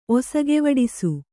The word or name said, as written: ಒಸಗೆವಡಿಸು